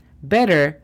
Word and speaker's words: better: Better, gramáticamente se escribe B-E-T-T-E-R. Así que bien, en esta tengo la opción de decir better, como lo acabo de decir, como si fuera una D. O también vas a escuchar que algunas personas digan better, better, y significa lo mismo.